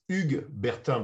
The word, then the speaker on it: Hugues Bertin: In French, is Hugues Bertin, but it's not pronounceable. It's impossible to say Hugues Bertin in English or in Spanish. Impossible.